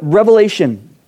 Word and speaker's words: Revelation: Now, if you're Hispanic, then you know that I'm saying it wrong because all Hispanics say the book of Revelations. It's multiple. I had to learn that over the years to say Revelation and stop at the end.